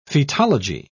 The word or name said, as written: fetology